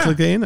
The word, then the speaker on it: Clegane: Clegane? – Yeah.